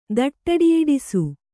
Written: ದಟ್ಟಡಿಯಿಡಿಸು

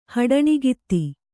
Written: ಹಡಣಿಗಿತ್ತಿ

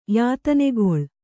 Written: ಯಾತನೆಗೊಳ್

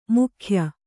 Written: ಮೂಗೆಯ್

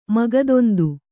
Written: ಮಗದೊಂದು

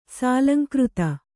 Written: ಸಲಿಂಗರತಿ

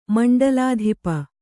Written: ಮಂಡಲಾಧಿಪ

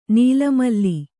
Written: ನೀಲಮಲ್ಲಿ

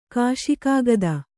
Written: ಕಾಶಿಕಾಗದ

